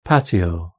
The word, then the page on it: patio: British English